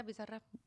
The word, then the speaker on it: Bizarrap: Bizarrap. How do you say that? – Yeah, Bizarrap.